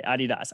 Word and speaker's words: Adidas: Adidas.